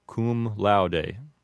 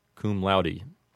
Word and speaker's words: cum laude: And then with just cum laude, a correct Latin pronunciation might sound something like cum laude. But the one we commonly hear is cum laude.